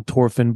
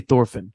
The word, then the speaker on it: Thorfinn: The story is about this kid, Thorfinn, In the anime, they call him Thorfinn, but it's spelled with a T-H. I say Thorfinn.